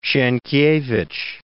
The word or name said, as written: Sienkiewicz